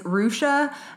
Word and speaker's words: Hrusa: And the last one is Rusha. And that doesn't sound very weird, but it's spelled H-R-U-S-A.